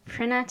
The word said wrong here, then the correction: T'Rina: Tapina? T'Rina. T'Rina, thank you. Mm. I was like, I'm saying her name wrong.